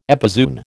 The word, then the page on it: epizoon: American English